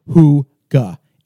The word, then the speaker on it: Hygge: And it says pronounced Hygge, H-O-O-G-A, but it's spelled H-Y-G-G-E.